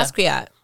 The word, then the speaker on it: Basquiat: Basquiat. – Yeah. Basquiat.